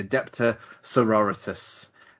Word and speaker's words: Adepta Sororitas: I came home with the codex for the Adepta Sororitas. I pronounce that right, haven't I, Matt? – That is correct.